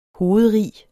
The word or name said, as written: hovedrig